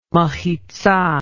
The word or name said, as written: mehitzah